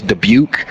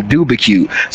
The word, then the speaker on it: Dubuque: His name is Dubuque. Dubuque. Dubuque, Iowa. the um the his name is spelled weird. It's hard to pronounce for idiot. And apparently one of our drill stars an idiot. They called him Dubacue.